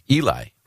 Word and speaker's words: Eli: E-L-I, which we would all, Americans would say, that sounds like Eli, right? Mm-hmm. And their birth announcement said, his name is Eli, which rhymes with jelly.